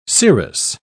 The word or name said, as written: cerous